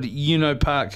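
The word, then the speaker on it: Ueno Park: I'll tell you what, to do a song called You Know Park, which is spelled U-E-N-O Park, it must be quite significant.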